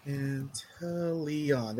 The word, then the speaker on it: Inteleon: Is it A or E? Or... – It's an I. – I, okay. – Inteleon. There we go.